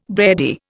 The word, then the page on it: beardie: Indian